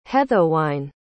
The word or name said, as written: haethowine